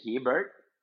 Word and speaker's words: Hebert: In English, Hebert. And in French, Hebert. Because where I live is the French part of Canada, Quebec.